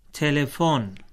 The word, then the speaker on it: تلفن: تلفن جی، یہ فارسی تلفظ ہے ٹیلیفون کا۔